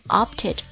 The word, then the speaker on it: opted: 再讲一下，动词以 t 或者 d 结尾之后，它的过去式加 ed 嘛，对不对？那它的发音呢会变成 ed，所以呢加了 ed 之后的 opt 就念作 opted.